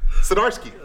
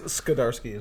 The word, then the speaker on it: Zdarsky: Chip Zdarsky. – And I think it was Skidarsky is what I heard.